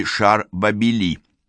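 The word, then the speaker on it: šar Bābili: «Царь Вавилона» звучало на местном наречии «шар Бабили».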